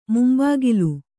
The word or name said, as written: ಮುಂಬಾಗಿಲು